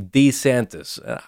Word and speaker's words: DeSantis: That's, again, yeah, he pronounces his name DeSantis. If that's how he pronounces it, that's how it's pronounced.